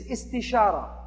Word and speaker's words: istishara: The second step of a decision-making process is istishara. That sounds kind of like it rhymes with istikhara.